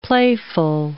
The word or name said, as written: playful